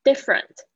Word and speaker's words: different: different 这个呢，nt 这个 t 也是非常小声，大家稍微注意一下。